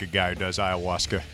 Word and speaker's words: Ayahuasca: Ayahuasca, I think is how you say that. Is that correct? – Yeah. – Oh, I did it right.